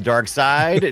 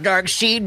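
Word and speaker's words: Darkseid: No, I bounce back and forth. Dark Side, Dark Seed. – Bounce back and forth because people seem to always tell you, no matter how you pronounce it, that you're pronouncing it incorrectly.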